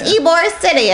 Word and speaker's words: Ybor City: Ybor City. – I was like, it's why. – If you guys ever listen to the whole stay, you'll know all about Ybor City. – It's Ybor City, and I was like, cool.